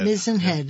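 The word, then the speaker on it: Mizen Head: So after Deb's birthday, you went to the southernmost place in Ireland, and I'm going to mispronounce M-I-Z-E-N, Mizenhead. – Mizenhead. – Mizenhead.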